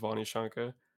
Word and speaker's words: Vanishanka: The longer version of pronunciation, my name is Vanishanka.